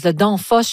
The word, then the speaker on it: Danfoss: Alors, on parle d'une compagnie de logiciels danoise, Danfoss, je ne sais pas si je le prononce comme il faut.